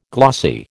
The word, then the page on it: glossy: American English